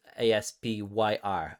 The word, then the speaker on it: Aspyr: Um, uh, made the uh, being worked on, developed by Aspyr. Uh, I think is how you say it. A S P Y R. I, I say Aspyr, but uh, you know, yeah, that sounds about right to me.